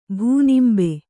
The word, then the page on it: ಭೂನಿಂಬೆ: bhū nimbe